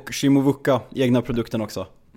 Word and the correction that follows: Chukwuemeka: Du menar Chukwuemeka, ja, som vi fick in från Aston Villa. – Där satt uttalet. Kör en gång till så vi lär oss. – Chukwuemeka, jag har övat på den hela hösten.